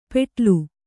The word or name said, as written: ಪೆಟ್ಲು